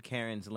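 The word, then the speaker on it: Caryn: It's spelled, I don't like the spelling of it either, but it's C-A-R-Y-N. – It's like Darren. Yeah, it's spelled like Darren. Available anytime, anywhere, Karen has been flawlessly cloned into an AI for your convenience and enjoyment.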